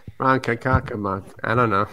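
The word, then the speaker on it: Ronkonkoma: Sometimes you got to switch at Ronkonkomo, that's usually where the switch happens for Greenport. – That's right. – You got to switch. – I didn't know how to pronounce it. – Ronkonkomo.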